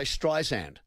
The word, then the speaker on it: Streisand: So we have to say Streisand. That's how you say her name. Barbara Streisand. Not Streisand. Streisand. It's good.